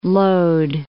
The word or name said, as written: Lod